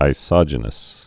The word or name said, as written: isogenous